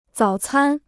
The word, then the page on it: 早餐: zǎo cān